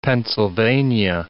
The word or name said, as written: Pennsylvania